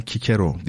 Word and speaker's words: Cicero: Şimdi Cicero aslen Kikero diye okunuyor bu ama Cicero olarak yerleşmiş Türkiye'de biz de öyle diyelim.